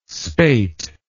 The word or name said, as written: spate